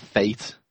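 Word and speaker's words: fête: Then they start talking about the feet, of course. It's spelled F-E-T-E with this weird French circumflex on the first E because it's a French word. – I'd pronounce that fate.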